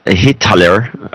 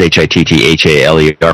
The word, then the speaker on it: Hitthaler: Thomas Holler uh, Hitthaler. You might want to try to pronounce his name. It's H I T T H A L E R from Frankfurt.